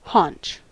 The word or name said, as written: haunch